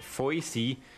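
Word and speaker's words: Voisey: And Robert, I'm pronouncing it Voicy. That's V-O-I-S-E-Y.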